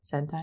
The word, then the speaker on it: centime: Is that how you say it? Centime? – Centime.